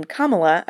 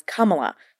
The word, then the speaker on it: Kamala: Moorjani says she pronounced her character's name as Kamala, how the name is traditionally said,